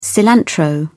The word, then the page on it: cilantro: British English